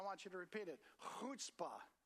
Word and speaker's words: chutzpah: It's spelled C-H-U-T-Z-P-A-H, chutzpah. Okay, so if you want to say Yiddish or Hebrew, you got to get the gutturally sound in your throat. So try to clear your throat with me right now, okay?